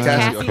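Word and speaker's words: Kasi: It's Cassie. – Oh, I was calling her Casey, so you're right.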